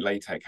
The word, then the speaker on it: LaTeX: Like LaTeX, I'm pronouncing it with the soft sort of k sound at the end. But when you write it, it's L-A-T-E-X. It's just that the X is a chi, and so hence the k sound.